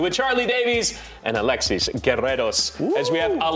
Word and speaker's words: Guerreros: I grew up in a Cuban household, I had to roll your R's for you. – Yes, thank you. Yes. Alexis Guerreros. – Guerreros.